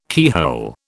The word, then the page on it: keyhole: British English